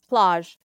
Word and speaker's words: plage: Can you pronounce it for me? – Yeah, it's plage. Demois plage, which means Demois beach. Plage. It means Ooh. – Beach in French.